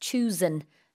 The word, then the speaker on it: choosin': 그럴 때는 발음도 그냥 N 발음으로 끝내시면 돼요. Mm-hmm. So it's choosin.